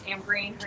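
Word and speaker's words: Amreen: So my name is Amberin Hernandez, which is spelled A-M as a monkey, as a bear, R-E-E-N as a Nancy, Hernandez, H-E-R-N-A-N-D-E-Z.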